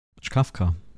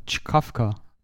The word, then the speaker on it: Czkawka: Das wird ausgesprochen, das ist jetzt polnisch. Ich versuche mich jetzt in polnisch. Czkawka. – Ja, Czkawka. – Czkawka. – So wird das ausgesprochen.